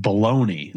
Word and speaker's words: bologna: Shout out to Bologna is pronounced baloney.